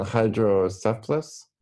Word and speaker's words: Hydrocephalus: but you grew up with Spina Bifida and Hydrocephalus? – Perfect, yep.